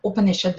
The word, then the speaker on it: Upanishad: So when we say Upanishad, there are three syllables in that, Upa, Ni, and Sad. Okay?